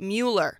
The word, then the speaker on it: Mueller: At Miller in East Austin. – Austin Mayor Steve Adler and Austin ISD Superintendent Paul Cruz pronounce it Mueller, but reporters at KXAN and KLRU pronounce it Miller.